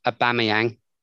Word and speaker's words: Aubameyang: Apparently, I'm going to try and say his name, Aubameyang. – Yeah, that's actually right.